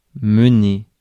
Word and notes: mener: France; [mə.ne]

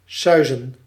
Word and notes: ronronner: France; [ʁɔ̃.ʁɔ.ne]

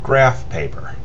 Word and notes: graph paper: American English

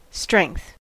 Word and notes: strength: /stʃɹɛŋ(k)θ/

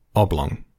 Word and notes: oblong: /ˈɒblɒŋ/

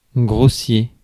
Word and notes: grossier: /ɡʁo.sje/; France

